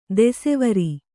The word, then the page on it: ದೆಸೆವರಿ: Kannada